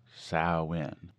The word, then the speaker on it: Samhain: The one that we are interested in is Samhain, pronounced Samhain, despite what Glenn Danzig would say.